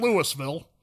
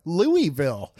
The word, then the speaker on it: Louisville: Well, no, you just have to say it like, okay, so the thing is, you just have to say it relaxed. Like, you don't have to be so uptight about it. Louisville.